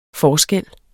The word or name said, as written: forskel